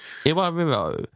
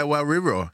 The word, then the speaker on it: Iwariro: Main, Iwariro. How was that? – Yeah, bang on. – I said that right? – Iwariro, yeah. – With caramelized Let's hear it again. – Iwariro.